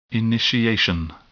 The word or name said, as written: initiation